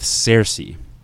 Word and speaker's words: Cersei: And that's who I really thought was interesting with Cersei. Sansa and Cersei, am I saying that right? – Yep.